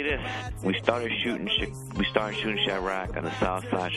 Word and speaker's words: Chiraq: How do you say it? Chirac.